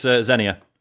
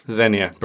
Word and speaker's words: Xenia: It's Xenia. – Xenia, brilliant. The K is silent.